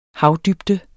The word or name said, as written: havdybde